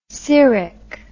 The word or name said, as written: seric